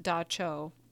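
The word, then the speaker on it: dab tsog: When asked about common nightmares, men and women described a figure called a Dab Song, pronounced Da Cho.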